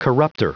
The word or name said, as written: corruptor